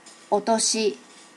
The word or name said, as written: otoshi